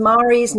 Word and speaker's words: Mhari: Because Mari's name is spelled M-H-A-R-I. And it's, I believe, the Irish spelling, Mari. The easiest way to remember it is like, will you marry me?